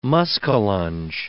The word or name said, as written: muskellunge